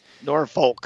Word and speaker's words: Norfolk: Norfolk. – You lived in the UK for long enough, to know that the L is silent.